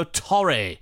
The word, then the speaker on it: Torre: Apparently the fee's going to be €5 million plus add-ons, while young midfielder Pablo Torre, or Tor, one of the two pronunciations, he goes to Girona in the other way for a loan for a season.